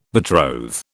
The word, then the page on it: betroth: British English